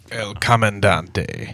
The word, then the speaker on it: El Comandante: who had a deep and lasting affection for El Comandante. – El Comandante.